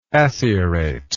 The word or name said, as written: acieration